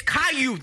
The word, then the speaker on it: Caillou: When have you ever heard of a silent L? – It's Caillou. That's how it's pronounced.